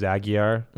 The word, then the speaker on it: Aguiar: So my name is, last name is Aguiar, like mm-hmm. Aguilar without an L, yep.